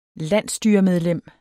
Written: landsstyremedlem

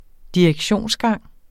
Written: direktionsgang

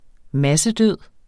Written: massedød

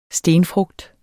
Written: stenfrugt

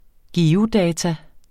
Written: geodata

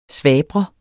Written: SVABRES

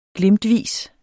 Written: glimtvis

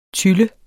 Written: tylle

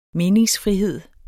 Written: meningsfrihed

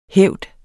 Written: hævd

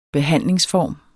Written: BEHANDLINGSFORMEN

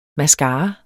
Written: MASCARAEN

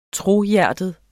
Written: trohjertet

